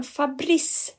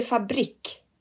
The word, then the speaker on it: fabrik: Säger man Fabrice eller fabrik? – Fabrik säger man. – Bra, tack. Det här är en stor sak för mig. Fabrik. – Ja, fabrik.